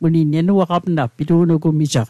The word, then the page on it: pitu'nugumij: Mi'gmaq